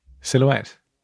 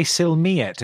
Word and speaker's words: silhouette: Silhouette. – I prefer my way. – So how do you say it again? – Do you say silmiette if it's a silhouette of you? – Silmiette, silhouette, silhouette, silhouette, silhouette.